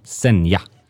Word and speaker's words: Zegna: Precis, och precis som du sa, Zegna. – Ja, om vi nu ska bryta fonetiskt ner det, är det inte Zegna?